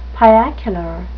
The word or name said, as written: piacular